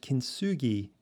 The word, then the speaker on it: kintsugi: And last year I discovered an incredible Japanese art form called kintsugi that recognizes this human experience. It's spelled K-I-N-T-S-U-G-I.